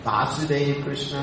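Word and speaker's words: Vāsudeva: Remember, listen to how I'm pronouncing this word. Vasudev Krishna and Vasudev. You can hear the subtle difference, Vasudev, Vasudev. Long A, short A.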